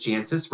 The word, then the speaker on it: Jancis: It's spelled J-A-N-C-I-S. Jancis, right? It's even better, Jancis. – Janice? – So the C is silent? – I don't know.